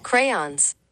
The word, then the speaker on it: crayon: According to the Merriam-Webster Dictionary, the proper pronunciation of crayon is in two syllables, crayon.